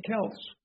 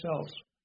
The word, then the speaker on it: Celts: Gomer produced the ancient Sumerians and Cimbri, from whom are descended the Celts or the Celts. There's two ways of pronouncing that.